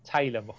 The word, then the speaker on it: Taylor Moore: But the run of form that Tyler Moore had... Taylor Moore. – You were right. It was Taylor Moore.